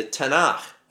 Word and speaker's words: Tanakh: T, okay, for Torah, Tanakh, and then this little A is silent. Well, I won't get into the Hebrew here, but then N and then K, the Tanakh. That's how it says. So T-N-K.